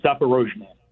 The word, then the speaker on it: Zaporozhye: I believe it's Zaporozhye. – There you go. You said it right, and thank you for that.